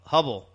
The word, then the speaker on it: Huebel: Rob Hubble. – Hubble. – It's spelled H-U-E-B-E-L. – Right, okay.